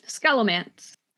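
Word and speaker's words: Scholomance: It's called The Sholomance, is the way it's pronounced.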